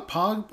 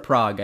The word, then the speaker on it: Prague: Was it a uh, was it Pro, was it Bra, Prague? How do you say it? – Prague.